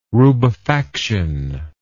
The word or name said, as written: rubefaction